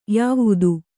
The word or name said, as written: ಯವತಿ